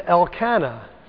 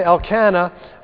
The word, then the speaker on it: Elkanah: Now, I used to say Elkanah, and I was doing some study in my self-pronouncing Bible here, and they say it should be pronounced Elkanah. So I'll try to say Elkanah, but I might say Elkanah.